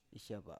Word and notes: isyava: Biak

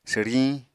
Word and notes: swriñ: apatani

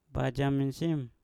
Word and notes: bajam mensim: Mpur

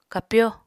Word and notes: kapyó: Apatani

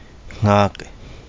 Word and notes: ngage: kapingamarangi

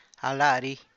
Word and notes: a: àaa’

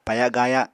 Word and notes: paya-gaaya: Apatani

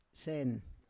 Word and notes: sen: Roon

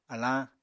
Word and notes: alañ: apatani

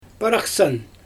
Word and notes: paraxsan: Xyzyl

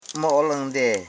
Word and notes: mooling: mo.o.ling; Koro

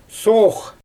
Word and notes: soox: Xyzyl

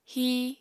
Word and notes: híi: apatani

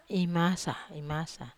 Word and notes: masa: Wamesa

